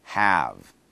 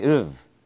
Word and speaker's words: have: H-A-V-E. Notice when I say it alone, it's one syllable. Have. It's not have, have, have. To make it easier at the beginning, you can make the V sound longer. V. Have. That will get you into the practice of pronouncing it the right way.